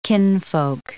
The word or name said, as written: kinfolk